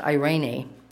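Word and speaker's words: Irene (εἰρήνη): So peace in the Greek is pronounced Irene.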